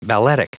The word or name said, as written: balletic